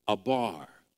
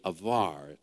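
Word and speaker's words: avar (עבר): In the Hebrew, and this is very interesting and important, the word for cross over is abar. It's really avar. It's A-B-A-R, but pronounced avar.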